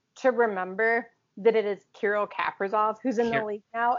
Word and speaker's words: Kirill Kaprizov: Kirill Kaprizov. – Kirill Kaprizov.